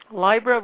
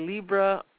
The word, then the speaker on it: Libra: Some people say Libra. I say Libra, just so that we both know that we're talking about the same sign. Different countries refer to it differently. Libra is a more French pronunciation, whereas Libra is more of a U.S. pronunciation.